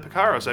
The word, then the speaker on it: Picaros: Picaros, is that right? – Yep, sure. – All right, Tintin and the Picaros.